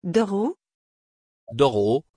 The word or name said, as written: Doro